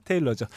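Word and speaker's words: Taylor: 마이크 타일러 아닌가요? – 테일러의 영어 발음은 타일러든 테일러든 그게 네. 그겁니다. 테일러죠.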